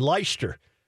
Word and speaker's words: Leicester: Leicester. How do you say it, Englishman? L-E-I-C-E-S-T-E-R.